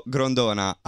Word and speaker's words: Grondona: Filippo Grondona.